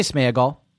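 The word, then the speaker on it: Sméagol: I would say Smeagol. – Okay. – I think the accent is on the E, and I would say it's Smeagol.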